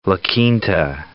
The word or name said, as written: La Quinta